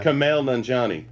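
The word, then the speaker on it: Kumail Nanjiani: Kamal Nanjiani.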